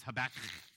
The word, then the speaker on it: Habakkuk: And it has that double K on the end, so it's Habakkuk, and you got to kind of get a sound in order to really pronounce it well.